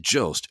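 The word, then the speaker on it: Jost: How do you guys say it? And she said Joost.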